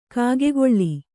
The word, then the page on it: ಕಾಗೆಗೊಳ್ಳಿ: kāgegoḷḷi